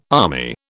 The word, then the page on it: army: British English